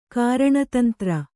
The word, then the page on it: ಕಾರಣತಂತ್ರ: Kannada